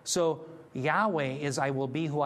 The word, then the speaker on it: Yahweh: because they're going from Hebrew and they're trying to put it into English, but Yahweh is the better pronunciation.